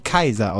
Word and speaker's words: Caesar: Der Kaiser, nicht wahr, der Imperator, der Kaiser, also meine Lateinlehrerin hat übrigens immer gesagt, dass Caesar eigentlich Kaiser ausgesprochen wird, wegen AE. Und wir Idioten immer nur sagen für AE, aber eigentlich heißt es Kaiser.